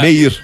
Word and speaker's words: Meir: É na rua Golda Meir, número 298, apartamento 406. – Acho que é Golda Meir. – Gold Meir. – Não, é Meir.